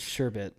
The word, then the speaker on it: sherbet: It's sherbet. Where's the ert? Where's the ert in it? There's no... it's not sherb and then E-R-T. – Yeah. – It's E-T. Like the alien.